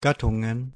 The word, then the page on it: Gattungen: /ˈɡatʊŋən/